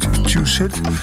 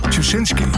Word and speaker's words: Tuschinski: En Tjusit, kan niet uitspreken. In Amsterdam, een heel luxe theater. Tuschinski.